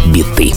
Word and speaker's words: біти: Наголос гурт ставить саме так: біти.